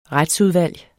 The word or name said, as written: retsudvalg